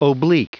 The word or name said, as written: oblique